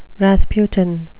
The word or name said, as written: rasputin